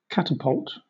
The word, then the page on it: catapult: British English